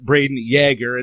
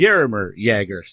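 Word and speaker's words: Jágr: There's another Jagr who is available this year, who you have ranked number 5, and that's the way I've been told to remember how to pronounce it, that it's Braden Jagr and not Jagr, that it's the same as nice. – Yaromir Jagr.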